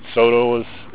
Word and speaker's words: Saulteaux: In Canada, they're called Sotos. It's a French word. – What is it? – Soto. It's spelled S-A-U-L-T-E-A-U-X.